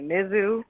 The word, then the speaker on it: Mizzou: Y'all Missouri people pronounce it like Mizzou, and I pronounce it Mizzou. So I'm pronouncing like y'all, Mizzou.